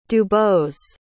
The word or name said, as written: dubose